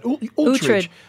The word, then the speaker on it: Uhtred: Uhtred.